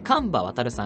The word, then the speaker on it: 神場渉: 神場渉さん、白樺のカバに、元旦の単に、順と書いて、神場渉と読むんですけれども、神場さんはですね、産業能率大学の講師であって、セミナー講演も全国規模でこなす人です。